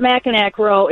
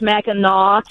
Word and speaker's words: Mackinac: But it's not Mackinac Row. It's Mackinac. – There's just two different spellings pronounced the same way.